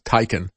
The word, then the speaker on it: Taycan: I think it's pronounced Taycan. It's T-A-Y-C-A-N.